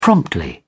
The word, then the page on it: promptly: British English